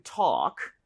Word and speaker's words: talk: 자, talk라고 하는 거 L 발음은 당연히 모음이죠, 그렇죠? 오케이, 그래서 발음할 때 근데 talk라고 하지 말아주시고요. – 네. – A의 강세를 주시고 그리고 입모양을 좀 벌려서 talk보다 오히려 talk 이렇게 talk. 어에 가깝습니다. 차라리, 오케이?